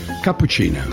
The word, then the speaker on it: Cappuccina: Come lo pronunci in inglese o americano? Cappuccina. – Cappuccina. – Ah, vedi? – Sì, esattamente così.